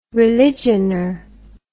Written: religioner

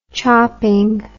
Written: chopping

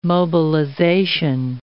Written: mobilization

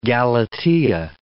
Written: Galatea

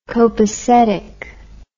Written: kopacetic

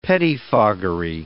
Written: pettifoggery

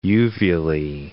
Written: uvulae

